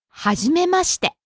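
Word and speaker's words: Hajimemashite (はじめまして): After saying hello, Mr. Tanaka said, Hajimemashite.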